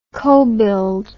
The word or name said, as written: cobuild